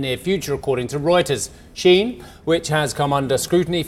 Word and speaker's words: Shein: Well, as the aforementioned Chinese online retailer, Shein, it's pronounced Shein,